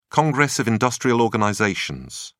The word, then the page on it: congress of industrial organizations: British English